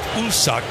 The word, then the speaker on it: Woosuk: You spell his first name, W-O-O-S-U-K, and it's pronounced Usuk.